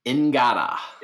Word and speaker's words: Ngata: Uh, how do you say his last name, Thomas? – Ingata. – Ngata. – Just like if, it's spelled. I didn't know if the N was silent.